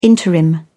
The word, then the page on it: interim: British English